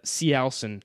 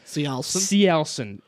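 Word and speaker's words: Cielcin: Cialson, that's I now know that's how you pronounce them. – Cialson.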